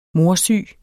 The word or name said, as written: morsyg